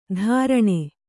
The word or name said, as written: ಧಾರಣೆ